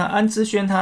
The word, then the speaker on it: 安之儇: 那为什么我前面讲那么多集都还是讲安之环呢？因为其实我在整理这个大纲的时候，我是用仓颉输入法，所以我根本就不会去碰到，不会去确确认说这个字到底要念成环还是轩哈。那总之。他那个念成轩哈，那这个看小诗长知识，那看啦啦队呢也可以增进你的国文造诣哈。那安之轩他在二零一九年的时候，